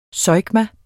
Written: zeugma

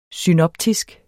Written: synoptisk

